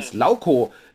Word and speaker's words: Slavko: Und ich glaube, wir müssen den eben Slavko nennen, so wie Slavko Ausenik oder Peter Preutz. Also dieses V in Vincics Vornamen wird wohl als U ausgesprochen.